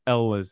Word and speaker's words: Elwes: Elwes.